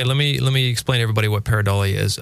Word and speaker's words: pareidolia: So that's a good case for pareidolia. Is that how it's said? – Yeah, because that's exactly what it is.